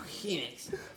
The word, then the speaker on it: Phoenix: Joaquin Phoenix. – Phoenix. – Oh yeah, Phoenix. Joaquin Phoenix. – You gotta visualize the word with an F and then an H.